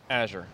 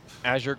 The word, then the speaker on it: Azure: How do you pronounce that? The official Mark Rusinovich way when you're in meetings and talking to people. – Azure. You can think of it as Azure going to the store.